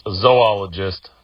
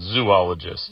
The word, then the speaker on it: zoologist: It's pronounced zoologist, not zoologist.